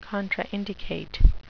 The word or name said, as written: contraindicate